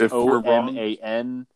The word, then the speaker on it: Oman: It's O M A N. Uh, o M A N. It's I believe it's Oman.